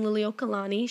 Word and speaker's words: Liliuokalani: The queen at the time is Queen Liliuokalani.